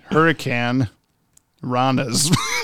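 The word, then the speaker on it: Hurricanrana: Well, yeah, but your pronunciation. – yeah, it's Hurricane Ranas.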